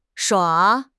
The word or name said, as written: 耍